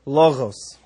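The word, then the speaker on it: logos (λόγος): What's the correct way to say logos in modern Greek? – Modern Greek is logos.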